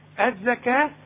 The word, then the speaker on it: الزكاة: الزكاة كل الزكاة بتاعة القرآن مكتوبة بالواو لكن تنطق بالألف.